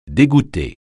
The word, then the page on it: dégoûter: French